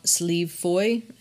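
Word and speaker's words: Slieve Foy: But in 1989, in Carlingford, Ireland, he claimed that he found evidence of a real leprechaun on a mountain called Sleeve Foy, and I don't know if I'm pronouncing that correctly. – I mean, that's the way I'd pronounce it, yeah.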